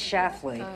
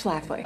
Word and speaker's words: Schlafly: Who the hell is Phyllis Schlafly? – It's Schlafly.